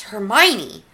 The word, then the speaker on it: Hermione: So, yeah, I remember my mom, like, heard us reading, and she's like, it's Hermione.